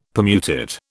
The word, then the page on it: Permutit: British English